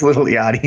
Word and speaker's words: Yachty: No, it's Lil Yachty.